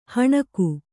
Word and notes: ಹಣಕು: Kannada

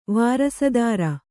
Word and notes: ವಾರಸುದಾರ: vārasudāra

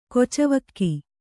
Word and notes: ಕಿಚ್ಚುವೊಗು: kiccuvogu

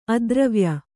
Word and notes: ಅದುರುವಾಯು: aduruvāyu

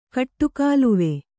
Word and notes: ಕಟ್ಟುಕಾಲುವೆ: kaṭṭukāluve

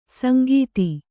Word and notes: ಸಂಗೀತಿ: sangīti

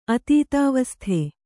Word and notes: ಅತೀತಾವಸ್ಥೆ: atītāvasthe

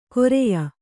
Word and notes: ಕೊರೆಯ: koreya

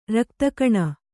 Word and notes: ರಕ್ತಕಣ: rakta kaṇa; Kannada

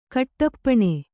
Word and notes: ಕಟ್ಟಪ್ಪುಣಿ: Kannada

